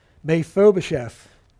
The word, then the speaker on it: Mephibosheth: Mephibosheth.